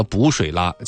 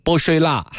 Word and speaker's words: 补水啦: 包括是那个东鹏特饮啊，他那个补水啦请的是姆巴佩代言的。姆巴佩呢用这个魔性口音把这个补水啦念成了波水啦。